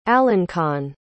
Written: alençon

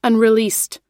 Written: unreleased

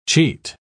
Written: cheat

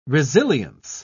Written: resilience

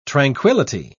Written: tranquility

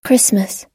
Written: christmas

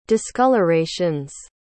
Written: discolorations

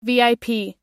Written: vip

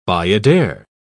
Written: bayadere

